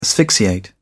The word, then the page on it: asphyxiate: British English